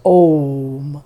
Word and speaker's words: Om: And whereas the cows could do the absolute brilliant Sandhi sound of Om, that's A, U, and Ma, Kara sound put together in Sanskrit, that becomes a Sandhi that is then pronounced Om,